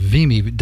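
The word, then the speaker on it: Wimme: That fellow by the name of Vimi, W-I-M-M-E. I guess it's pronounced Vimi.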